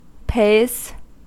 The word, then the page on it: pays: American English